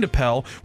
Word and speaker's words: Appel: Is it Appel? Am I saying that right? – Yeah. – Appel, okay. Mark Appel had a strong outing in his much-covered debut after becoming the longest number one overall pick to make his debut.